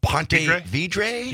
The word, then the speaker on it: Ponte Vedra: I can never pronounce the name. Ponte Vedra? – Vedra, yeah.